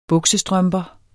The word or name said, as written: buksestrømper